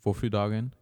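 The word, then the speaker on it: Vårfrudagen: Et euh, on en, apparemment, en prononcé en suédois. Donc, c'est Våfru Dagen. Et ça ressemble à Waffeldagen, Waffeldagen, Waffeldagen.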